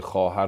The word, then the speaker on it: خواهر: واو واو معدول است خانم شهرزاد انگاری که تلفظ نمیشه که اصلا در مثلا واژه خواهر رو امروز ما مینویسیم واو دیگه نمیخوانیم